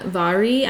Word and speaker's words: Mhairi: But it's Vari, I'm probably saying that right, McFarlane. – Yeah, I've had a lot of her books on my TBR. – Yes, so it's spelled, if you're going up to look for this later, it's spelled M-H-A-I-R-I.